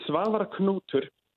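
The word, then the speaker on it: Svavar Knútur: I think the funniest way I've ever heard it pronounced was I heard this Australian radio host say, an Icelandic friend, how do I pronounce this? Swa kachichua, which was really interesting. Kachichua. It's Svavar Knutur. But the way you can just think about it, you can just think about a pirate, and he's like really dressed to the nine.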